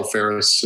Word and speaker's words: Fehres: So it's Karl Fehres.